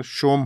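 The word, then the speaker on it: Som: thank you thank you सोम thank you so much यहाँ से नाम से और आपने अपने नाम के उच्चारण के बारे में लिखा है एस ओ एम है लेकिन उच्चारण शोम होगा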